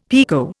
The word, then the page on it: pekoe: Indian